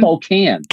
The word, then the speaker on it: Spokane: This, this in Spokane, if that's not how you say it, it started. – Spokane. – Yeah, Spokane. No, what is it? Spokane. – No, Spokane. – Spokane. – Can.